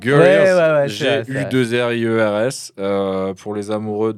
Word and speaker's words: Gorillaz: Comment Guerriers très difficile à prononcer pour nous français. – Gorillaz. – Ouais, Gorillaz, excellent.